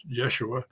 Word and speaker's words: Yeshua: One is Yeshua, who is, that's the pronunciation in Aramaic of Joshua, which of course is the same as Jesus.